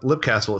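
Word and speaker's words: Leap Castle: So I made mention of this castle, Lip Castle. It's spelled like leap, but it's literally one of the most haunted castles in Ireland.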